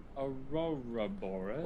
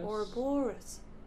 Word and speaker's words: Ouroboros: We, we can do the Aurora Bo- Aur- Aurora Boris. – Ouroboros.